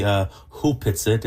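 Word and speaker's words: Opitsaht: It's written Opetsit, but it's Opetsit. Actually pronounced properly Hupitsit.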